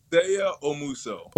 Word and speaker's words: Seigha Omuso: Seigha Omuso.